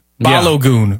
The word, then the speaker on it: Balogun: I think it's Balugan. You got to say it like Hadouken in Street Fighter.